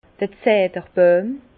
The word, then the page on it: d'r Zederboijm: Alsatian; Bas-Rhin; Herrlisheim